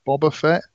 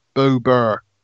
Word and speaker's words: Boba Fett: Pronounce it Boba Fett. Boba Fett, not Bober.